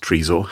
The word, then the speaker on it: Trezor: that I had received an email telling me that Trezor, or tr- I think it's pronounced Trezor, had been hacked. Do you know what a Trezor is? Well, uh, if it's French, trésor, it's like a treasure.